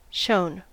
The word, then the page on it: shown: American English